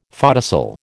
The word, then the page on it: photocell: American English